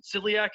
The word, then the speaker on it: celiac: And if I'm correct, I mean, correct me if I'm wrong, with your situation with, is it celiac? Is that how you pronounce it? – Yes.